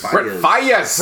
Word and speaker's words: Faiyaz: Brent Faiyaz.